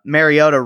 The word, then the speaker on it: Mariota: And you have made yourself a little bit of headway with the Titans fan base by pronouncing Mariota right. You know, that's pretty important. Mariota is the pronunciations of the name go back and forth, but when you pronunciate it right, the fans really appreciate that.